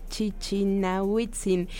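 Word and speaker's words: Chichinautzin: a ver si ahora sí lo digo bien, sobre el campo volcánico del Chichinahuitzin. Ana Lilian, lo dije bien, bienvenida a Ibero 90.9, gracias por tomarnos la llamada. Gracias a ustedes. Este sí, lo dijiste bien.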